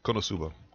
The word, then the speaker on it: Konosuba: This Konosuba? Am I saying that right? – Konosuba, God's blessing on this wonderful world.